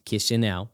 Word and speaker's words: Chișinău: Did I say that right, Chișinău? – Chișinău.